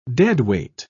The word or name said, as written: deadweight